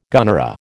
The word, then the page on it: gunnera: American English